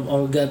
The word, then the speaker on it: Olga: Olga Olha itu sebenarnya yeah. pengucapan aja bedanya kalau Olga bicara bahasa Rusia kalau Olha bicara bahasa Ukraina tapi sebelum Olga kan ada si Igor.